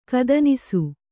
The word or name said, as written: ಕದನಿಸು